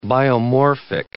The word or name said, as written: biomorphic